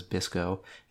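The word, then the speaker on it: BSCCO: There are YBCO, BSSCO, so we pronounce that one as BISCO, and HBCCO.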